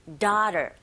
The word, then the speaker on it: daughter: 그 다음 daughter라고도 들릴 수도 있지만 daughter, daughter 이런 식으로 a, u 부분이 아에 가깝게 들릴 수도 있습니다. daughter, 영국식은 아마 조금 다를 것 같죠?